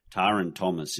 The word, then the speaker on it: Taran Thomas: So Taran Thomas is the one you actually keep in that forward line. And just to correct everyone, that's the correct pronunciation of his name.